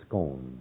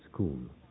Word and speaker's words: Scone: If you read it, you think it's Scone, but the local denizens would look at you as an ignoramus, so don't pronounce it Scone, they pronounce it Scone.